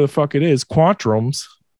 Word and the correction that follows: quatrain: is that how you say it? – Quatrain.